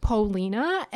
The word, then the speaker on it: Paulina: But you know, there's just a lot of things with pronunciation of the names, as we in Poland, we put the stress on Pau, and here they would like to say Paul, like Paul, Paulina, and I just got used to it.